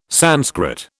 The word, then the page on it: Sanskrit: British English